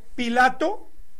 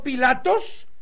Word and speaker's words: Pilato: Repito, Pilato y no Pilatos.